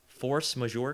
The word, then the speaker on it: force majeure: force majeure clause.